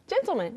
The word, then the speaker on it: gentleman: 이게 신사를 가리키는 gentleman이라고 하지 않는 것처럼 gentleman. – gentleman. 네. yeah. gentleman. freshman. – 한 단어로 들어가면서 뒤에 나오는 man이 약간 약해지면서 man이 됐어요.